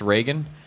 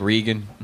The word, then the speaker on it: Reagan: So I found the you want to impress white people part most interesting because you mispronounced Reagan. You're like, I like Regan.